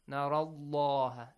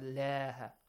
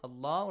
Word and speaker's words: Allah: Narallah, this heavily. Narallah, law, law, not lah. Narallah.